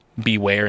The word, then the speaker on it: Bewear: And for those who can't get a visual or don't know who Beware is, A, it is spelled B-E-W-E-A-R. Beware and bear mixed together,